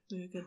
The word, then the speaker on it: Muirgen: The name may also relate to the Irish Murgan. M-U-I-R-G-E-N. Murgan.